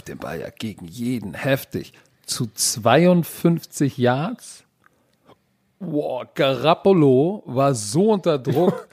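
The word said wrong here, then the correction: Garoppolo: äh, Garapolo. Was hast du das gerade ausgesprochen? – Garappolo.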